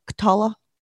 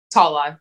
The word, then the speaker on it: Tola: So I found Tala here, and I say I'm saying that right, correct? – Tala. Tala. Yep. – Tala. Yeah, yeah. And it's spelled like T O L A.